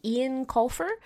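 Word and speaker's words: Eoin Colfer: So it's written by, I think, Ian Colfer is how you pronounce that.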